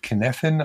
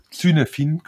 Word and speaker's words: Cynefin: auch hier würde ich mal empfehlen, ähm, wenn irgendjemand weiterlesen will, mal zu recherchieren, zu googeln nach dem Begriff Kneffin, also schreibt mir den C-Y-N-E-F-I-N, Zynefin quasi, aber wird mhm. Kneffin ausgesprochen.